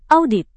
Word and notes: audit: indonesian

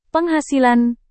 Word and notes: penghasilan: indonesian

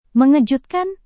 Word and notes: mengejutkan: Indonesian

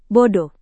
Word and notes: bodoh: Indonesian

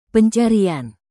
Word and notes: pencarian: Indonesian

